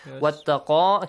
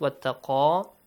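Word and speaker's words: وَاتَّقَى: Wattaqa, yes. Actually I prolonged it, that was wrong. Do not prolong it. Wattaqa, only alif mat.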